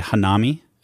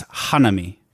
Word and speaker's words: Hanami: So we would look at that word and say Hanami. – Okay. – But I believe in Japanese, it's Hanami.